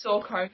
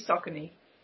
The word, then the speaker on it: Saucony: Australia says Saw Coney, but it's meant to be Saucony. But all Australians say Saw Coney.